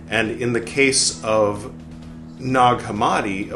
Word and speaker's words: Nag Hammadi: So it's spelled like nag, but pronounced more like eggnog, right? Nag Hammadi, Nag Champa.